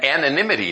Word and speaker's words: anonymity: And anonymity, that's how you say it. Didn't look like that in print.